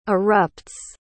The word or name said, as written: erupts